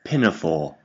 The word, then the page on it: pinafore: British English